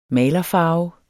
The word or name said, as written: malerfarve